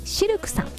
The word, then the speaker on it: CILQ: そして南青山にフレンチレストランシルクさん、 CILQ と書いてシルクさん、